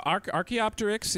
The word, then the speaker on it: Archaeopteryx: Like, where does Archaeopteryx? Am I pronouncing it correctly? Archaeopteryx. – Yeah. Archaeopteryx is an early bird that existed during the Jurassic period, so actually quite early in the dinosaur era.